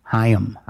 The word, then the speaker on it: HAIM: Pronounced, it says pronounced in its capital H, capital Y, dash, lowercase am. Haim.